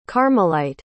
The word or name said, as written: carmelite